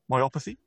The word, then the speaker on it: myopathy: saying it correctly, myopathy? – Yeah, you're saying it correctly, yeah.